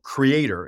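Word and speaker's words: KR38R: And then we have a product offering, and our products are called Creator, and it's spelled K-R-3-8-R. K-R-3-8-R.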